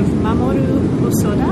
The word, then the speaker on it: Mamoru Hosoda: And this is the figure of Mamoru Hosoda. Am I pronouncing that sort of not entirely wrong? – That's just right. Just perfect.